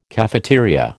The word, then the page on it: cafeteria: American English